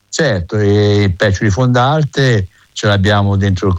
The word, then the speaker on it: Peccioli: Mi ha detto: Peccioli, la E è chiusa, mica siamo a Milano, ma si pronuncia Peccioli.